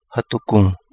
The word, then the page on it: Hadu Gungu: Kapingamarangi